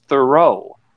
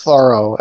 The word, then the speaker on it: Thoreau: When I was in school and learning, it was your last name was Thoreau, but that's not correct, right? – Well, no, my name is pronounced Thoreau, as in I do a thorough job.